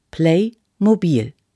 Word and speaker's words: Playmobil: Die Aussprache von Playmobil ist so: Playmobil. Play ist Englisch. Es heißt spielen.